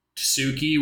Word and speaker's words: tsuki: and sounds a lot like Tsuki with like a silent T, mm-hmm.